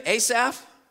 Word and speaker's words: Asaph: Does that name sound familiar to you, Asaph? Or as it's pronounced in the Hebrew, Asaph? Asaph.